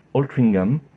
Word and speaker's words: Altrincham: ce nom n'a pas été francisé, donc il faut le prononcer plutôt à l'anglaise, Altringham.